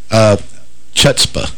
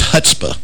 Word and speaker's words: chutzpah: Anyway, Donald Trump was introduced to the Republican Jewish Coalition Thursday by a supporter who touted the presidential candidate's chutzpah. – Nope. – Did I say that right? – I think it's chutzpah. Chutzpah. Sorry. The C is silent.